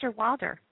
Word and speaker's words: Osterwalder: Osterwalder.